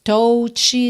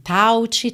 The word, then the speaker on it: touch: Se escreve T-O-U-C-H, mas você não diz touch nem touch, tá? É touch, touch.